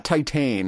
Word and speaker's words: Titane: It's not Titane, it's Titana? – Titan.